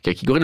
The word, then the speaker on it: kakigori: Prononciation vraiment kakigori.